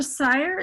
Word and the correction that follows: Sayre: is that how you say it, Marcia? – It's Sear. – Sear, Marcia Sear, I should have asked ahead of time, is head of outreach services.